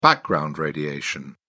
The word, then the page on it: background radiation: British English